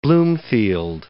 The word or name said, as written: Bloomfield